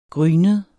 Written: grynet

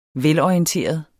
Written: velorienteret

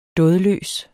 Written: dådløs

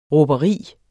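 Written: RÅBERI

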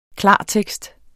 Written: klartekst

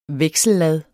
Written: veksellad

